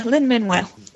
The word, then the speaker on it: Lin-Manuel: Lin-Manuel.